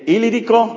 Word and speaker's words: Ilírico: Desde Jerusalén, la parte más al sur donde ministró, hasta el Ilírico, así se pronuncia, es más o menos donde está ahora Yugoslavia o el área de Ucrania.